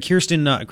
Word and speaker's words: Kirstjen: Homeland Security Secretary Kirsten Nielsen, whatever her name is. It's a Kirsten, I think. K-I-R-S-T-J-E-N, the silent J.